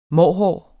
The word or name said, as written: mårhår